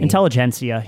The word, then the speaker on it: Intelligentsia: I always butcher the fucking name. – Intelligentsia. Yeah.